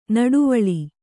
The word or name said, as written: ನಡುವಳಿ